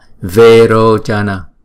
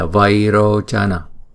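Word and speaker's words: Vairocana: Chữ vairochana sẽ được phát âm là verochana thay vì là vairochana.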